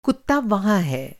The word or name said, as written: वहाँ